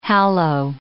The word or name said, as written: hallow